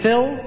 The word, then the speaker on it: Phil: My name is Phil, that's P-H-I-L. Hammerslaw, I better spell that for you. It's H-A-M-M-M-E-R-S-L-O-U-G-H. – H-A-M-M-E-R-S-L-O-U-G-H.